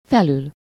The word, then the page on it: au-dessus: France; [o.dø.sy]